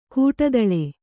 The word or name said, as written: ಕಡಿತಲೆ